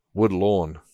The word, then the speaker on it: Woodlawn: Woodlawn. – Oh, Woodlawn. – Woodlawn. Sorry, I might have said that wrong the first time too.